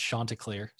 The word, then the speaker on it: Chanticleer: Oh, it's actually pronounced Chanticleer, so they have that on their website.